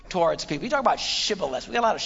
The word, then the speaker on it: Shibboleth: And they'd say to him, "Say, all right, then say Shibboleth." And if he said Sibboleth, because he couldn't pronounce the word correctly, they'd seize him and kill him.